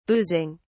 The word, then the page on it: boozing: British English